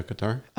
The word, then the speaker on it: Qatar: New Zealand, Estonia, Ireland, Qatar? – Oh, yeah, Qatar. – Oh, wow, I said it right.